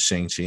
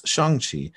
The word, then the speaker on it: Shang-Chi: And I can remember even as a kid of not really caring much about whatever a Shang-Chi is, because we thought we thought the pronunciation was Shang-Chi. And it wasn't until this that we learned it's Shang-Chi.